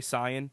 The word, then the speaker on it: Saiyan: But that's why we say Saiyan, and we don't say it differently than everyone else. We just say it believe it or not, we say it correctly.